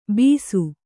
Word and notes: ಬಿಸು: bisu

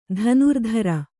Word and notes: ಧನುರ್ಧಾರಿ: dhanurdhāri

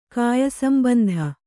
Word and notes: ಕಾಯಸಂಬಂಧಿ: kāyasambandhi

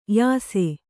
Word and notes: ಯಾಸ: yāsa

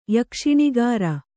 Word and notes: ಯಕ್ಷಿಣಿಗಾರ: Kannada

